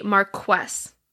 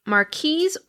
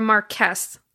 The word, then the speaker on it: marquess: There's a title that you can have in Britain, and I called it a marquess. That's not how you say it. It is a marquise or marquess. Either one of those seems to be acceptable.